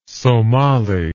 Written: Somali

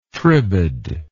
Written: tribade

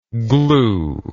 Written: gluer

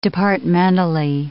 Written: departmentally

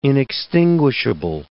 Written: inextinguishable

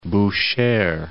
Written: Bushehr

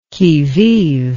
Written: qui vive

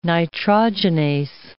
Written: nitrogenase

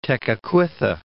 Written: Tekakwitha

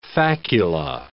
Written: facula

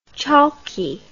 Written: chalkie

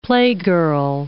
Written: playgirl

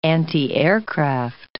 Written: antiaircraft

